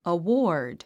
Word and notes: award: əwɔ’ː r d; アウォード

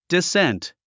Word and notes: descent: disént; ディセント

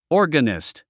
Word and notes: organist: ɔ́rgənist; オルガニスト

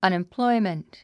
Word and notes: unemployment: ʌ̀nimplɔ́imənt; アネェンプロォイメント

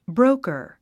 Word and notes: broker: bróukə r; ブローカァ

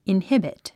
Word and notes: inhibit: inhíbit; インヒビト

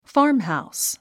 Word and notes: farmhouse: fɑ́ː r mhàus; ファームハウス

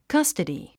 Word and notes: custody: kʌ́stədi; カスタディ

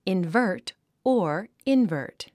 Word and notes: invert: invə́ː r t; インバート

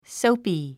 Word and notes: soapy: sóupi; ソウピィ

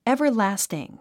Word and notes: everlasting: èvərlǽstiŋ; エバァラスティン

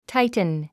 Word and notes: tighten: táitn; タイテン

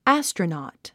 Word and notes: astronaut: ǽstrənɔ̀ːt; アストラノート

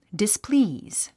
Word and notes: displease: displíːz; ディスプリーズ

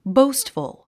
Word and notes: boastful: bóustfəl; ボウストフル

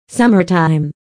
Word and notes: summertime: sʌ́mə r taim; サマタァイム

